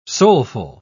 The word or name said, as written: soulful